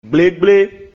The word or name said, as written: Gblĕgblé